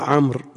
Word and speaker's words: عمرو: قال حدثنا، قوله حدثنا عمرو بن خالد هو الحراني وبفتح العين وصحف من ضمها، قال عمر بن خالد، صوابه عمرو بفتح العين، والواو جعلت للفرق بين عمرو وعمر، إذا سلم من ضبط العين تفرق بينهما هذه الواو. ويخطئ من ينطقها بالواو في جميع الحالات،